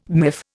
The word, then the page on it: miff: Indian